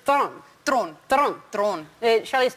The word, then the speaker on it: Theron: Ella fue un episodio de Ellen DeGeneres y ella le preguntó que cómo se pronuncia el apellido y ella dijo que Thron, porque ella es de Suráfrica. – Sí, sí, sí, yo sabía esa Suráfrica, ese apellido se pronuncia así.